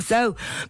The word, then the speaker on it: Sault: did you hear the new album from So? Because, So, it's spelled S-A-U-L-T.